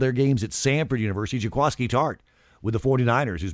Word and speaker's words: Jaquiski: No, it's Jaquaski. It's spelled Jaquiski. it's spelled Q-U-I-S-K-I, but it is Jaquaski, and the 49ers folks finally got it right.